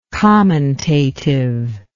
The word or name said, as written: commentative